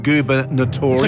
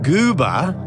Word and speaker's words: gubernatorial: But the way it's sounding phonetically, goober, G-O-O-B-E-R. What is it? Goober? – Gubernatorial. – Notorial. N-A-T. You are I-A-L. Come on. – Incorrect. Oh. Gubernatorial, as in the election that elects the governor, G-U-B-E-R-N-A-T-O-R-I-A-L.